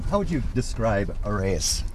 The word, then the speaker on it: Arreis: How do you say A-R-R-E-I-S. – Arrayus.